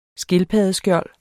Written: skildpaddeskjold

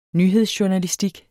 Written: nyhedsjournalistik

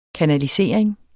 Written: kanalisering

